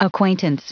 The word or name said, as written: acquaintance